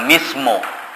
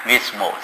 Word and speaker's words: mizmor: 那用那个希伯来用 mizmor 以后就你们读马来文、印尼文也同样发音，也是从 mizmor 这个 M-I-Z-M-O-R 这个字的发音。